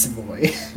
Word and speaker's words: Savoie: I think the other one's pronounced Savoie, so... – It is pronounced Savoie.